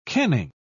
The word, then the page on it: kenning: British English